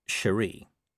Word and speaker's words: Cheery: Corporal Cheery Littlebottom pronounced her name Cherie.